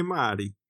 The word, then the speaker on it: Marty: Vad är det korrekta, korrekta uttalet? – jag vet inte, är det Marty eller Marty eller Mardy? Eller vad säger vi? – Det borde ju vara något italienskt Bronx-aktigt. – Mm. – Är Mardy. Ja, där, där ja. har vi det.